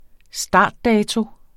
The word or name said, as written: startdato